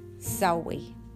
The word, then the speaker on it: Samhain: Samhain. You can read all about it. We know it as Halloween, but it's actually Sowie. It looks like it says Samhain, but it's Sowie. That's how you pronounce it.